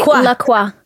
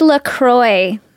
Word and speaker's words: LaCroix: I heard you don't pronounce it like the French would pronounce it, which would be LaCroix. I think it's LaCroix.